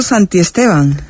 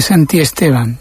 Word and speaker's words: Santisteban: Lo has dicho mal, no es Santisteban. – Santisteban. – Santisteban. – Santisteban. – A él le ponía de los nervios cuando le escribían Santisteban. – Pues fíjate que lo he dicho mal, pero lo tengo bien escrito, porque yo sé que es Santisteban, pero no sé por qué he dicho Santisteban.